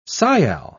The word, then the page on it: sial: British English